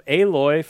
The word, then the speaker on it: Aloy: Aloy, I said that right? – Yep.